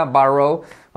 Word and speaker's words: borrow: Fíjate cómo se pronuncia borrow. Cuidado con el borrow, okay. Te vas a caer. Cuidado con el borrow. Acuérdate, así se pronuncia, borrow.